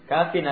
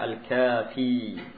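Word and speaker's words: Kafin (كافٍ): Kafin artinya mencukupi. Kafin. Ini cara bacanya kalau tidak pakai al. Kalau pakai al dibaca al-kafi.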